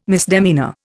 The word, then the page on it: misdemeanour: Indian